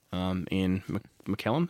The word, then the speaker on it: Ian McKellen: And that was, I'll get to it in a bit, but it was like pretty full on for Ian McKellen.